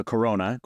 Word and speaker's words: Corona: I'll say his name's a gentleman, but his name is Fabrizio Corona. So Corona with the accent on the A.